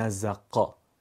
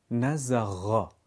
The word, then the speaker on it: نزغا: نمیگیم نزقا نزغا. کششی، سایشی، دنباله دار.